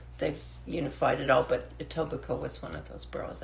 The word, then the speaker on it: Etobicoke: Etobicoke. Of this, Etobicoke.